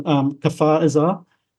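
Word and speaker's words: Kfar Aza: And again, I'm sorry for my mispronunciation. Kfar Azar. – Yes.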